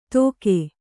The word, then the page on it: ದೊಗೆ: doge